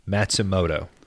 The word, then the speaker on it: Matsumoto: Matsumoto. M-A-T. S-U-M-O-T-O. Matsumoto.